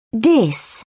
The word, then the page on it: dis: British English